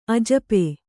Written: ಅಜಪೆ

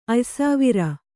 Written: ಅಯ್ಸಾವಿರ

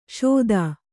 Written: ಷೋದಾ

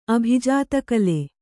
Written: ಅಭಿಜಾತಕಲೆ

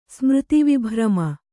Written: ಸ್ಮೃತಿವಿಭ್ರಮ

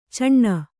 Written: ಚಣ್ಣ

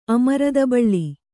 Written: ಅಮೃತಬಳ್ಳಿ

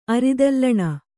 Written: ಅರಿದಲ್ಲಣ

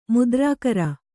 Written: ಮುದ್ರಾಕರ

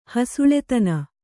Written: ಹಸುಳೆತನ

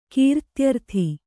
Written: ಕೀರ್ತ್ಯರ್ಥಿ

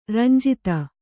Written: ರಂಜಿತ

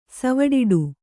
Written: ಸವಡಿಡು

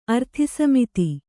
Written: ಅರ್ಥಿಸಮಿತಿ

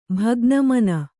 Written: ಭಗ್ನಮನ

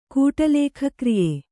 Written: ಕೂಟಲೇಖಕ್ರಿಯೆ

